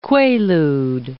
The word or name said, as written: quaalude